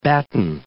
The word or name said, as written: batten